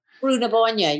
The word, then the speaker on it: Brú na Bóinne: Is it uh, Brewer Brewer Nibonia? – Brú na Bóinne, yeah, Brú na Bóinne, yeah. Is that the way? Yeah, yeah.